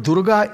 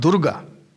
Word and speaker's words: Durga: Durga is, in mantric context, the word Durga is not Durga. It's not long A.